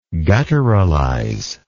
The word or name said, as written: gutturalize